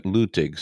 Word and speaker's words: Luttig: By the way, I thought it was Luttig too, but they were all pronouncing it Luttig, so that's, I guess, what it is.